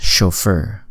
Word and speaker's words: chauffeur: But it says C-H. – Mm-hmm. It's pronounced chauffeur. C-H-A-U-F-F-E-R. Spell it. – C-H-A-U-F-F-E-U-R. – Yeah, chauffeur. – But usually C-H is pronounced ch. – True, but it's a French word, chauffeur. I believe it's French. But it is chauffeur.